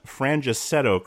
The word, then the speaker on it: Frangicetto: I am joined today by Colin Frangicetto, correct? – Oh, yeah.